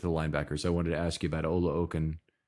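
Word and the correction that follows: Oluokun: if I pronounced his name there correctly. – Aluakon. – Aluakon, okay. So, when is he used?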